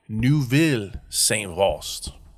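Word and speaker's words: Neuville-Saint-Vaast: They were in rotations between the trench and the ruins of Neuve Saint-Vaast. Sorry, I said that wrong again. Neuville Saint-Vaast.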